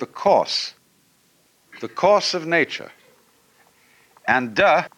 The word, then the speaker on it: Te: And the book is called the Tao Te Ching, because again, there's no apostrophe after the T, so it's pronounced de, and Jing.